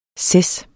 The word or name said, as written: ces